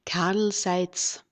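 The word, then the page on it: Karl Seitz: kaʁl zaɪ̯ʦ; Austrian German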